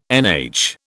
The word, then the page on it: NH: British English